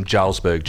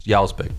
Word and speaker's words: Jarlsberg: Like, people, whenever you're at a supermarket, people go, oh, I'll have some Jarlsberg cheese. It's like, give me a break. That's not how it's pronounced. Jarlsberg, the J is silent.